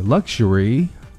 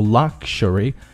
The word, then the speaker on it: luxury: luxury, luxury, OK, 这个是经常读错啊 luxury, 它的口型不要太大不要读 luxury, 那样就不对了Luxury, luxury, luxury shouldn't be something that you have on a rare occasion.